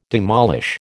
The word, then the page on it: demolish: American English